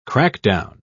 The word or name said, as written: crackdown